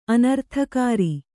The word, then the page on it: ಅನರ್ಥಕಾರಿ: anarthakāri